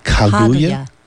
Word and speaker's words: Kaguya: They'd have the emphasis on the first syllable? – Kaguya. – Kaguya. – Kaguya? K-A-G-U-Y-A? – Yeah, Kaguya. That sounds very Japanese to me.